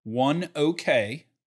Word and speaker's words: ONEOK: As I was listening to this morning on CNBC driving in, ONEOK, that's how they pronounced it, ONEOK bought Magellan.